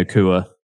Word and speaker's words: Nacua: But do you think there's a chance that Puka Nacua takes the wide receiver 3 role? And would that even mean anything Nacua? I've been saying Nacua like an idiot all this time. – Yeah, Nacua.